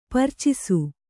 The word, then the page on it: ಪೆರ್ಚಿಸು: percisu